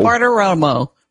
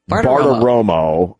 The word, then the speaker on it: Bartiromo: Bartiromo, Romo, Bartiromo. – Bartiromo. – Bartiromo.